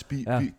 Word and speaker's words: skyr: Det hedder faktisk rigtig skirte. – Man skal udtale det skirte. – Christian Bitz siger skirte. – Og når Bitzen siger det, så gør vi det også. Ja, det er jo præcis det, de siger på Island. – Præcis.